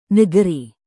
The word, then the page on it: negeri: indonesian